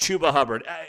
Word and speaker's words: Chuba Hubbard: It's pronounced Chuba Hubbard.